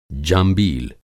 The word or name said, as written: जांबील